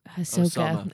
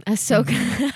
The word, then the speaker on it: Ahsoka: Osama. – Ahsoka.